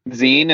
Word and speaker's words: Zine: Is it Zine? Is that how you pronounce it? – That's how Reggie Rocket always taught me to pronounce it. – Ah, yeah. That's a good one. Okay. Yeah, that's the right way to say it.